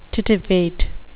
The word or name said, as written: titivate